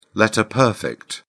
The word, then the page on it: letter-perfect: British English